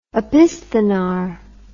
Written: opisthenar

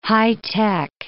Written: high tech

